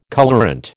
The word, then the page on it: colourant: American English